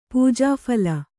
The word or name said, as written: ಪೂಜಾಫಲ